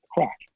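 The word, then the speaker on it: craic: We spell it differently. It's C-R-A-I-C, crack.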